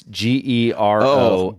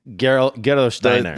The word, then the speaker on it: Gerolsteiner: It's out of Germany, And it's called, I'm going to butcher this name, but it's Gerolsteiner. Gerolsteiner.